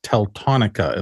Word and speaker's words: Teltonika: Well, if you're listening to the show rather than watching, just wanted to know, I kind of mispronounced that pretty badly. If you're looking it up, it's Teltonika, it looks like, Teltonika.